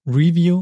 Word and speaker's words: review: Tatsächlich ist das für das Substantiv korrekt, für das Verb wird die letzte Silbe betont. Also ich reviewe, aber ich lese ein Review.